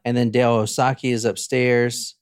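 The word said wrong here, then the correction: Okazaki: And that is not how you say her name. I'm sorry. Okasaki is upstairs.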